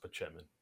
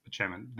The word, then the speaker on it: Spaceman: It's pronounced Pachemin. Yes. – It is pronounced Pachemin.